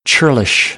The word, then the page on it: churlish: American English; ɝː